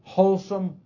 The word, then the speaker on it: Holsum: Matter of fact, I imagine most of us recall a bread company called Wholesome. H-O-L-S-U-M. They didn't spell it the same. But the pronunciation and the sound mimic the W-H-O-L-E-S-O-M-E. Wholesome.